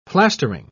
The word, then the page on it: plastering: British English